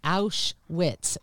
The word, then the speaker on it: Auschwitz: January 27th, it's the anniversary of the liberation of Outs, Auschwitz. Say it right. It's morning. Auschwitz, I am not yeah.